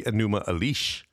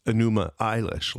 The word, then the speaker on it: Enuma Elish: the Enuma Elish, or is it Enuma Eilish, like Billie Eilish, but it's spelled a little different. Enuma is E-N-U-M-A, Elish is E-L-I-S-H, so it could be Elish or Elish.